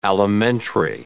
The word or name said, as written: alimentary